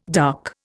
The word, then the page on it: dark: Indian